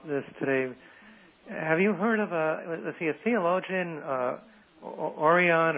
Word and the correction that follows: Origen: or I'm not sure how you pronounce it. – Origen. – Origen, yeah.